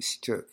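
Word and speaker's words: s-chuk: So the color black is, we pronounce it Shchuk in Otham, but Chuk is like the idea of black, like infinity.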